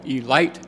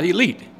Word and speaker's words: Elite: Mr. Simmons would say, if you talk to anybody, make sure you pronounce it the Baltimore Elite Giants, not elite, but Elite Giants.